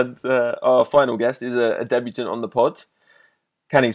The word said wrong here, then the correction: Channy: Sports, or Canny, is that right? Am I saying it right? – It's Channy. – Channy, okay.